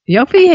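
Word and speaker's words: Jofi: Let's go with Dr. Freud, Sigmund Freud, and his is it pronounced Yofi? Yofi, yeah.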